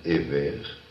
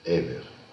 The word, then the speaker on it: Éver: Abraham est un descendant de Éver. Ça s'écrit en français E-V-E-R avec une apostrophe devant le premier E. Éver.